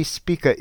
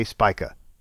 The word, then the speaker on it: Spica: Speaking of confusing, you'll hear the star's name pronounced both Spica and Spica. It's a Latin word meaning ear of grain, and technically Spica is correct, but you'll find plenty of dictionaries and stargazers who say Spica.